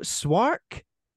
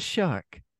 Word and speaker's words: Szwarc: Swark is spelled S-Z-W-A-R-C. – Shark, maybe then. – So the sequel, Shark. Are you saying this guy's name is Geno Shark? – It's actually Shark, yeah.